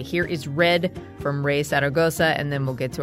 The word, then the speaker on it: Zaragoza: It's Zaragoza. – Zaragoza. – Yeah, okay. Perfect.